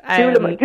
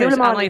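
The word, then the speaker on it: Dúlamán: I'm not sure 100% how to pronounce it, but I know it is by the Sleeve League Distillery Company. Dooleman, I think, is how they actually pronounce it. – Dooleman, yes, that's Dooleman, it.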